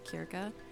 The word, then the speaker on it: Circe: If you've never read Circe, or if you've never heard of Circe, technically her name is pronounced something along the lines of Circe, if you're going off Greek mythology.